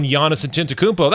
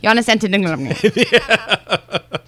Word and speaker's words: Antetokounmpo: And there's another dude who also is a favorite for MVP and Defensive Player of the Year, one Giannis Antetokounmpo.